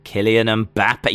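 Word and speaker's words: Kylian Mbappé: Kylian Mbappe. You did really well there.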